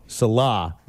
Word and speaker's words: Salah: Salah. – Salah. Mm-hmm. I think it's spelled Saleh, but pronounced S-A-L-A-H.